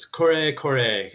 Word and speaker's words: Kore Kore: Is it Cory Cory? Am I pronouncing that correctly? – Well, this is where Dawn Datadub would critique us all, but it's Kore Kore as it's a Japanese pronunciation.